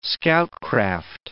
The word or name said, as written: scoutcraft